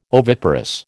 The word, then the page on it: oviparous: American English